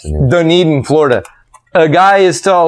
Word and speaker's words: Dunedin: Dunedin. – Dunedin? Is that how you pronounce it? – Dunedin.